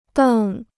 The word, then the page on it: 瞪: dèng